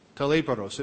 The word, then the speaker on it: talaiporos: Taleporos. It's spelled T-A-L-A-I-P-O-R-O-S.